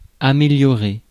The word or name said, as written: améliorer